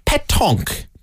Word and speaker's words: pétanque: Shirley has texted in, Colm, the correct pronunciation of pétanque is pétanque.